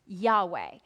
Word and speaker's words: Yahweh: And our next slide is going to show you that where you see Lord with the capital L and the small caps O-R-D, if you were reading in Hebrew, you would actually see the letters YHWH, and those would be pronounced Yahweh. In Hebrew, you don't put in the vowels, and so it makes it very difficult to read.